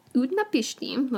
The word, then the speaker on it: Utnapishtim: An elderly man by the name of Utnapishtim. So that's Utnapishtim. Let's go with that pronunciation.